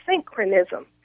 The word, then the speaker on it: syncretism: And that's spelled S-Y-N-C-R-E-T-I-S-M. Again, it's pronounced synchronism. It's spelled S-Y-N-C-R-E-T-I-S-M.